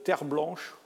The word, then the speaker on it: Terblanche: C'est un petit garçon qui s'appelait Gert Terre Blanche. Vous noterez la consonance Terre Blanche, ça s'écrit T-E-R-B-L-A-N-C-H-E, mais je pense que c'est à l'origine un nom français, Terre Blanche.